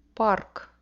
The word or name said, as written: park